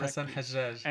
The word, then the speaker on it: Hassan Hajjaj: I did a trip to Paris and I saw a photographic exhibition by Hassan Hajjaj. I hope I'm saying his name correctly. – Hassan Hajjaj.